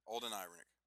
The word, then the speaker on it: Alden Ehrenreich: How do you say that? – Does it matter? – Alden Ehrenreich. – Ehrenreich? – Yeah. Alden Ehrenreich.